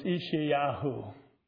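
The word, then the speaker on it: Yeshayahu: Like if you pronounce Isaiah in Hebrew, it's Ishayahu, Yahu, Okay.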